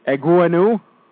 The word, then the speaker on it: Egwuenu: EGWUENU? – Yeah, it's right.